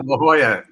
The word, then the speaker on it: La Jolla: La Jolla.